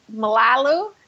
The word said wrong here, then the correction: Malolo: I think no. it's Navidi and Malolo.